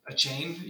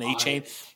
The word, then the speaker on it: Achane: Uh, it's the Devin cone or Coney. – A chain. – A chain? Yeah, I'm sorry. It's a chain. – Devin Achane? – Yeah, yeah, the Texas A&M speedster.